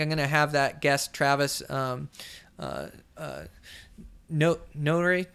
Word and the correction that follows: Nowitny: Jesus, I butchered his last name. Hold on a second. God dang it. I had this. I'm always messing up his last name. It's Nowitny? Yeah, Travis Nowitny. Okay, I got it.